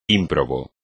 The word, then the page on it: ímprobo: espagnol